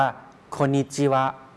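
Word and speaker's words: โ คนิ จิวะ (こんにちは): โคนิจิวะ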